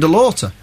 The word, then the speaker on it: DeLaughter: This is I've always pronounced it Tim DeLaughter, but I've just been told it's Tim DeLaughter. – Yeah, that's correct. – Oh, okay, fair enough. – It's spelt DeLaughter.